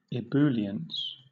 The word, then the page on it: ebullience: British English